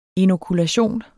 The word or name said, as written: inokulation